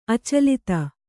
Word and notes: ಅಚಲಿತೆ: acalite

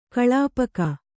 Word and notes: ಕೊಳ್ಪೋಗು: koḷpōgu

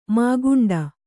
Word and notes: ಮುಕ್ಕಂಡೆ: mukkaṇḍe

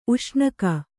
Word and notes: ಉಷ್ಣಿಕ್ಕು: kannada

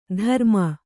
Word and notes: ಧರುಮ: dharuma

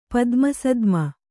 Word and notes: ಪದ್ಮಸದ್ಮೆ: Kannada

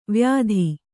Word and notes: ವ್ಯಾಧಿ: vyādhi

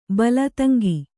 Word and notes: ತಂಗಿ: kannada

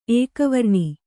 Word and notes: ಏಕವರ್ಣ: ēkavarṇa

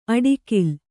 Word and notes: ಅಡಿಕಿಲ್: aḍkil

